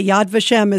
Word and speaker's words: Yad Vashem: And by the way, Yad Vashem is exactly how you pronounce that.